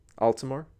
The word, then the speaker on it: Altomare: Altmore. – Is that how you say it? I think that's how I heard it pronounced, but it's spelt Altomare is how it's spelled.